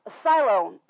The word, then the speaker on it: Siloam: Siloam, that's how it's said.